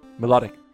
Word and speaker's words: Milotic: Milotic.